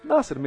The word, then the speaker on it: Nasser: O Amir Nasser sempre teve equipes na Fórmula 3, na Stock Car. – Primeiro de tudo, qual é a pronúncia certa? – Nasser mesmo, não tem...